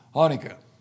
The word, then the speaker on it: Hanukkah: Is that the way you say it? Hanukkah? The Jewish Hanukkah? Okay, I said it wrong, sorry. Hanukkah.